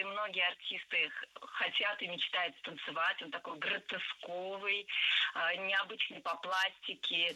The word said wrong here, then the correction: гротесковый: Гротесковый, неверно. Правильный вариант – гротесковый. Кстати, если мы хотим образовать от существительного «гротеск» прилагательное, то предпочтительным вариантом будет слово «гротескный» с однозначным ударением на втором слоге. Вариант «гротесковый» тоже допустим, но ударение на втором слоге сохраняется. Гротеск, гротескный, гротесковый.